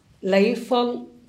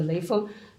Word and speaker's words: Lei Feng: Li Fang was the, tell us about Li Fang. – Okay. Lei Feng, his, yeah, in Chinese pronunciation, the Lei Feng.